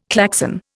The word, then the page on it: Klaxon: Indian